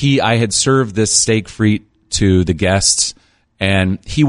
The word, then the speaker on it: frites: Now, you're going to see if it's spelled frite, like F-R-I-T-E-S, mm-hmm. steak. It looks like steak frites, but it's the S is silent. It's French. Mm-hmm. So it's steak frite.